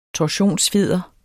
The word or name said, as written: torsionsfjeder